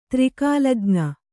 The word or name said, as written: ತ್ರಿಕಾಲಜ್ಞೆ